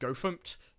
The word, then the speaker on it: gofmt: They have a tool called GoFmt, which is actually pronounced GoFmt, GoFmt.